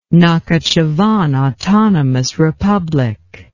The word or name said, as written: Nakhichevan Autonomous Republic